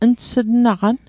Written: ns'tnaqan

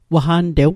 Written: waqantew

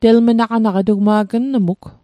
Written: telmnaqanaq

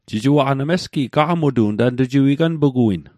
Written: gaqamutoq

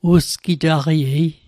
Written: usgitaqaiei

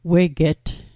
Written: weget

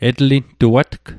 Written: etlintuatg

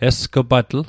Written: esg'patl

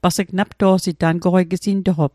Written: napto'sit

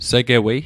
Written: segewei

